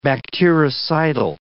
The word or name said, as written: bactericidal